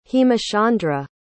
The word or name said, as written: hemachandra